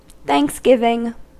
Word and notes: Thanksgiving: [θæŋks.ˈɡɪ.vɪŋ]

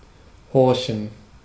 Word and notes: horchen: /ˈhɔʁçn̩/; /'hɔʁçən/

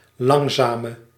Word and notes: slow: /sloʊ/; /sləʊ/

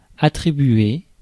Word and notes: attribuer: [a.tʁi.bɥe]

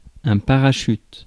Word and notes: parachute: pa.ʁa.ʃyt; France

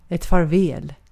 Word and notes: näkemiin: /ˈnæ.ke.miːn/

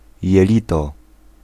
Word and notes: jelito: [jɛˈlʲit̪ɔ]

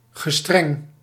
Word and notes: строгий: /ˈstroɡʲɪj/

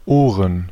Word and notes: Ohren: /ˈoːʀən/

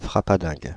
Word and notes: frappadingue: /fʁa.pa.dɛ̃g/; France (Île-de-France)

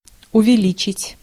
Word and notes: увеличить: /ʊvʲɪˈlʲit͡ɕɪtʲ/

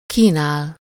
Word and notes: offrir: [ɔ.fʁiʁ]; France

